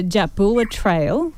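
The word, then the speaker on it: Jatbula Trail: Now, Ez has just completed the Jabula Trail. Am I saying it right? – Yeah, you are.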